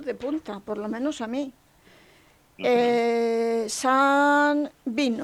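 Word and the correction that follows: Sawney Bean: Sauni Bin.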